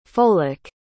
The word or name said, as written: folic